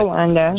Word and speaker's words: Jolanda: Is it Yolanda or Jolanda? – I don't want to say. – Jolanda. – Joe with, okay, I don't want to say your name wrong. I wasn't sure if it was a soft J, like yogging.